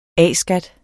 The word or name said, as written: A-skat